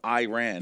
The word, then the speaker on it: Iran: In the game against Iran. Yeah. – Right. Saying it right? I'm not one insulting nobody. Iran? – Iran, that's Iran. Correct.